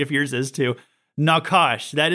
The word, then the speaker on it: nachash: The word serpent is the Hebrew word nakash. Okay, it's spelled N-A-C-H-A-S-H. That's how it's like, I think you call it transliterated into English, nakash.